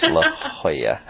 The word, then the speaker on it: La Jolla: So it's spelled J O-L-L-A, but it's pronounced La Jolla.